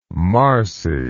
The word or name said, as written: marcy